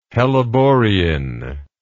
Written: helleborein

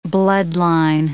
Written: bloodline

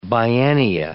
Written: biennia

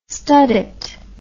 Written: studette

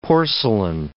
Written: porcelain

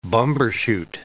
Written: bumbershoot

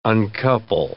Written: uncouple

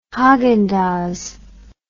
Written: haagendazs

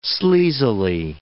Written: sleazily

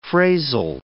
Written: phrasal

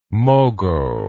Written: mogo

